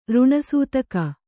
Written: ಋಣಸೂತಕ

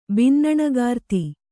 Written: ಬಿನ್ನಣಗಾರ್ತಿ

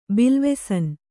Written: ಬಿಲ್ವೆಸನ್

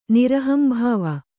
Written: ನಿರಹಂಭಾವ

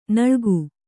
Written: ನಾಲಿಗೆ

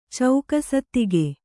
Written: ಚೌಕಸತ್ತಿಗೆ

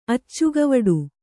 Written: ಅಚ್ಚುಗವಡು

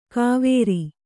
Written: ಕಾವೇರಿ

